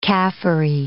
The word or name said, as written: Kafiri